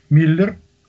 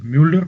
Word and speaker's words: Мюллер: Например, у нас принято считать, что якобы автор, один из авторов нормандской теории, профессор Миллер, или точнее, правильно говорить все-таки Мюллер, потому что он был Мюллер, он якобы был лютым ненавистником всего славянского и русского, и благодаря ему вот представление о славянах во всем окружающем мире было искажено.